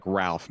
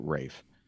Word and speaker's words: Ralph: It looks like Ralph, but he pronounces it Rafe.